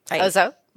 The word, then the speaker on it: Ozow: And so I said that right. – Ozow? – Oh, Ozow. I knew I would get something wrong. The easy one I got wrong. All right.